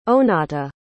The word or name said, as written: onatah